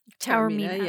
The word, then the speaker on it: Taormina: And he points out that the location is, it's shot in several places in Sicily, but the hotel is in Taormina. I don't know how to pronounce it. – Taormina, yeah.